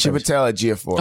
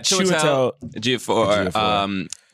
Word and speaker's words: Chiwetel Ejiofor: Chiwetel Ejiofor. – Oh, yeah. Chiwetel Ejiofor.